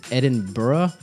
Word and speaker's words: Edinburgh: I think they pronounce it Edinburgh. – Burrow.